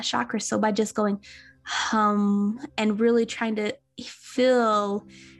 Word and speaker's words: HAM: This one would be, it's spelled H-A-M, so like ham, but it's actually pronounced as hum, so like a hum.